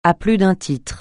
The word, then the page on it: à plus d'un titre: francais